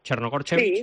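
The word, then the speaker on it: Crnogorčević: Tenim la blaugrana Anna Chernogorchevic. Anna, buenas tardes. – Buenas tardes. – Lo he dicho bien, ¿no? Chernogorchevic. – Sí, muy bien.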